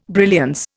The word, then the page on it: brilliance: Indian